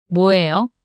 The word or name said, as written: 뭐예요?